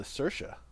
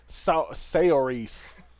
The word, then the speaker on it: Saoirse: Saoirse Ronan in Lady Bird. – Nice pronunciation on the Saoirse. – Yeah, I'm with that one. – Good with you. – Saoirse? – Saoirse.